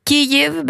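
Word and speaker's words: Kyjiv: Sen har vi Kyiv, betoning på första stavelsen.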